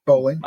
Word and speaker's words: Bowling: And you got Drew Marvick, Kansas Bowling, however you say her name. – Bowling. – I'd say bowling.